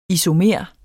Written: isomer

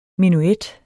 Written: menuet

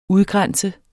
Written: udgrænse

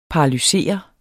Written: paralyseret

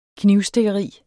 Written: KNIVSTIKKERI

